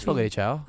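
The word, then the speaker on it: Fogo de Chão: I just wanted to It's Fogo de Chao.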